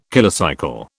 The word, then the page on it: kilocycle: British English